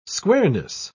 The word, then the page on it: squareness: British English